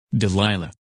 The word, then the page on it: Delilah: English